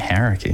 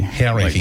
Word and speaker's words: Hauraki: Forgot how to pronounce it, though. Yeah. Well, yeah, because you get used to saying Hauraki. – Hauraki. – Like when you grow up here and then you leave and it's like, no, it's time to learn how to say it properly.